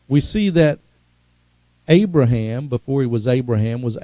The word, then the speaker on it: Abraham: In the Jewish language, they have that huh sound, and they very prominently pronounce it, Abraham, and they really, you know, down from the down from deep in the inside, Abraham, and they bark it out.